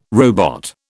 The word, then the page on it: robot: British English